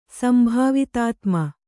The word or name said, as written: ಸಂಭಾವಿತಾತ್ಮ